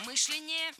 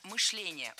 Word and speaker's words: мышление: Мышление неверно. Правильно, мышление. Ударяем второй слог. Кстати, раньше допускалась двойная норма ударения в этом слове. Но сейчас вариант Сергея считается устаревшим, а литературная норма только мышление.